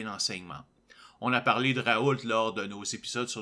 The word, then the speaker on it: Raoult: Juste pour rappeler à nos auditeurs, Didier Raoult, d'après ce que je vois, il faut, prononcer le T.